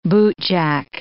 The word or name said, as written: bootjack